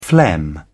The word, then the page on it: phlegm: British English